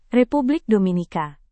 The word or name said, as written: republik dominika